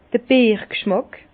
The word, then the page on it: d' Bierg'schmàck: Bas-Rhin; Herrlisheim